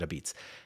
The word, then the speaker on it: apizza: I'm not exactly sure of the whole story on this, but A-P-I-Z-Z-A, but they pronounce it a beats.